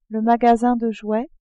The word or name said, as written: magasin de jouets